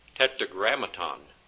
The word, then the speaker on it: Tetragrammaton: Number 36, tetragrammation. Tetragrammaton is the correct pronunciation.